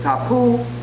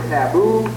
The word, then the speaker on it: tapu: or the way that Maori say it in a way, it's tapu, although of course we tend to say taboo, but it's the same kind of a concept. Tapu is a way of maintaining, of controlling your relationship to the outside world.